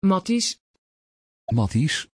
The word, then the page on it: Mattis: Dutch